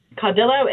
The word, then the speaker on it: Caudillo: Caudillo is the way we say it, yes.